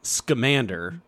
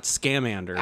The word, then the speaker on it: Scamander: Number one, it's Scamander. Not Scamander. – I depends where you're from. If you're from Minnesota, it's definitely Scamander.